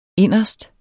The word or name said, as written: inderst